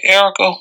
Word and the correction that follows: Arike Ogunbowale: whatever. – Arike Ogunbowale.